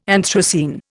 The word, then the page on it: anthracene: Indian